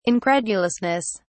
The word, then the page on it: incredulousness: British English